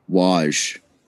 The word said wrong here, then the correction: Woj: It's Woj.